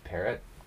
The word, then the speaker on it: Perret: It's Parrot, pronounced like the bird, but spelled differently.